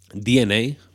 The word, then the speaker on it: DeNA: Primero, tenemos la de Nintendo y Dena, DNA, perdón, pronunciemos con propiedad,